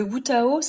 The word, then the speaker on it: Wutao: Et là, vous parlez du Witao, oui, Tao, j'espère que je prononce bien, qui éveille l'âme du corps. Pouvez-vous m'expliquer ? – Le Wutao, c'est un art que j'ai rencontré, donc W-U-T-A-O,